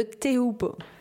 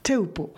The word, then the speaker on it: Teahupo'o: alors c'est le spot de Teupo. Teupo, voilà, qui s'écrit T-E-A-H-U p 2 Voilà, ah.